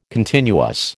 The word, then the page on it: continuous: American English